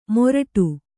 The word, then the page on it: ಮೊರಡು: moraḍu